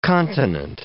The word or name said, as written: continent